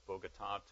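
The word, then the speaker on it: Bogotá: And so we flew down to Bogota, and you got to put the accent on the last syllable, Bogota, in order to say it correctly, on an Avianca plane, and then flew about 50 minutes, excuse me, west of Bogota to a place called Pereira.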